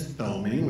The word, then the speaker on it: Thoeming: So first of all, my last name is Thoeming, which we probably mispronounced. It's T-H-O-E-M-I-N-G, and my first name is Norm.